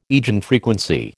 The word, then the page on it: eigenfrequency: American English